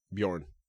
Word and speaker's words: Bjorn: If I spell out the letters B-J-O-R-N, how do you say that? – Bjorn. – What was that? – Bjorn. – Thank you. Thank God.